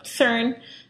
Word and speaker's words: CERN: did I even say it right? Or is it Kern? – No, I don't even know. – that would just be too strange if it were pronounced Kern. No, CERN. CERN is good.